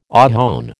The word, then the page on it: ohone: American English